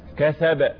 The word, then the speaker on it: كسب: هل الباء مشددة أم ساكنة؟ – ساكنة أم مشددة؟ ها كسب هكذا يبقى تب النطق تب عشان الباء مشددة إنما كسب الباء ساكنة وليست مشددة